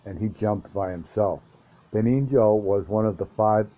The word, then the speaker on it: Benigno: I don't know if I say this right. B-E-N-I-G-N-O. I call him Benigno. Benigno. That's it.